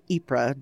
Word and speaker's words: Ypres: Everybody knows Battle of the Somme, Battle of Verdun, and Ypres, or Ypres, depending on how you pronounce it.